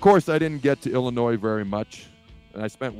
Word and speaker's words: Illinois: It's a short week, but we're going to have plenty of time to talk about Illinois where the S is silent as this week goes along.